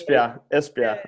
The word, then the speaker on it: Esbjerg: Now with Esbjerg, am I right? Have I said that right? I never get this. Esbjerg.